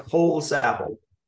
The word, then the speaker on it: Holsapple: Did I pronounce that right? – Like Holsapple. – Holsapple.